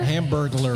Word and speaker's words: Hamburglar: I believe it was the Hamburglar Robble GIF. – Did you say Hamburglar? – Hamburglar. – Because it sounded like you added a couple extra syllables, I heard Hamburglar.